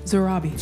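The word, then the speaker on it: Sarabi: How do you say her name? – Great question. – Zarabi. – That's right.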